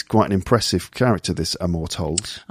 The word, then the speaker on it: Amor Towles: Amor Towles. – That's right. Towles.